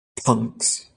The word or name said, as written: punks